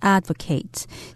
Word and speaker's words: advocate: Yes, and as a side note, the word advocate also has a verb form, but it would be pronounced advocate. So a person could advocate children's rights that person would be an advocate of children's rights.